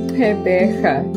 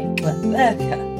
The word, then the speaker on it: Pebecha: Pebecha, yes. Pebecha. – So the emphasis is on the second syllable, is it? – Yes. – Okay. Pebecha.